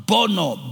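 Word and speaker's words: bono (בנה): The Hebrew word used there is the word bana, B-A-N-A-H, or bono. It's actually pronounced bono, B-A-N-A-H.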